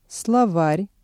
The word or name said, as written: словарь